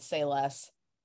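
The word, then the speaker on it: Sei Less: It's like pretty close to Times Square called Say Less. – Cute name, love it. – Yeah, great name. It's like Asian, so it's spelled S-E-I less, so it's like a play on words.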